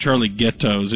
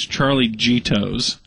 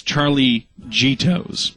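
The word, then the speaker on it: Charlie Gitto's: It's not pronounced Charlie Ghetto's. It's Charlie Gito's. We should have known that. – Should we have? – Yeah, with a little rudimentary Italian. We know that.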